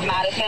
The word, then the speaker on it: Madison: My legal name is Madison. It's M-A-D-I-S-O-N.